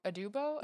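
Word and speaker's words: Aduba: Adubo? – Yeah, that's right.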